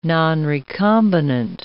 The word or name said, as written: nonrecombinant